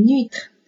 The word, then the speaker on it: huître: 我们一起来看一下，une huître，une huître。那么在法语当中啊，只要你见到这个字母 h，它都是不发音的，它不参与拼读啊。